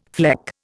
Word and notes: fleck: Indian

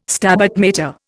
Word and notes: Stabat Mater: Indian

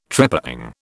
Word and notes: trepang: British English

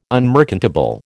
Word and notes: unmerchantable: American English

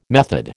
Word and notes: method: American English

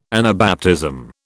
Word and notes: Anabaptism: British English

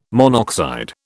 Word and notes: monoxide: British English

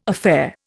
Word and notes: affair: Indian

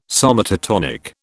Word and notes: somatotonic: British English

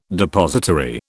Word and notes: depository: British English

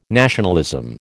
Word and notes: nationalism: American English

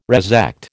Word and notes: resect: American English

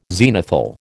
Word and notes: zenithal: American English

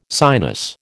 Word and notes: sinus: American English

